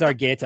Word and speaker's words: Argueta: Yeah, you're going to keep going with that soft G in Argueta too, huh? – Oh, yeah, it's Argueta.